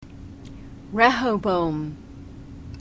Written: rehoboam

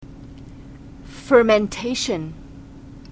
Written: fermentation

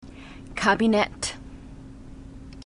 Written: kabinett

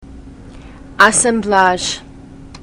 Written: assemblage

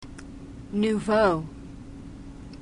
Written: nouveau

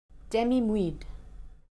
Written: demi-muid

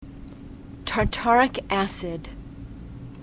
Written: tartaric acid

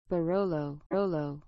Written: barolo

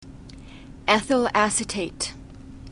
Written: ethyl acetate

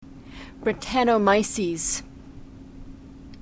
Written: brettanomyces

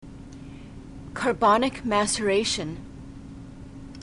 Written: carbonic maceration

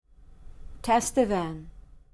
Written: tastevin

